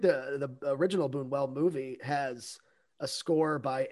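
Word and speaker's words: Luis Buñuel: Like Boonwell, the guy who made The Exterminating Angel. – Louis Boonwell.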